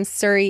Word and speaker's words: Surrey: His name is Charlie Baxter, and he is an ESL teacher from Surrey, England.